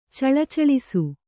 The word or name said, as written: ಛಳಛಳಿಸು